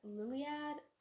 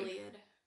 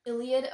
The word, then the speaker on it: Iliad: And on the topic of remakes, The Song of Achilles is basically a fan fiction based on, is it Iliad? Liliad? I literally don't know how to pronounce it. I'm so sorry. – Iliad. – Iliad, okay, by Homer.